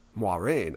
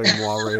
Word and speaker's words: Moiraine: See, it's for me, I feel like I have to say, because I believe in the book, the accent is on the moi part. So it's like Moiraine. Yeah, Moiraine.